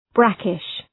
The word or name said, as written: brackish